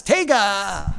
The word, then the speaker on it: Taiga: T-A-I-G-A.